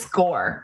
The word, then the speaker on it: SCOIR: It's SCORE. Everyone I've ever, like, I think they pronounce it SCORE. It, for those of you listening and wondering what the heck we're talking about, there's an acronym for this software, college software platform, and it's SCOIR.